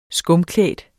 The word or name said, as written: skumklædt